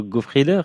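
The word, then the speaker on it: Goffriller: C'est aussi un album et joué Vivaldi, Ophélie Gaillard, qui rend honneur à votre instrument, le Francesco Goffrieder, c'est comme ça qu'on prononce? – Oui, absolument. Bah, c'est vraiment une basse vénitienne.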